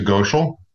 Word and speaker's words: Gochoel: It's actually Gochoel. It's okay. – Gochoel. – Yeah. – God, that was close. – I just like to say it rhymes with social. So simple way to remember.